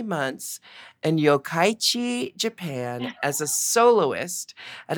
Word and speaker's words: Yokkaichi: Yokkaichi. – Yokkaichi.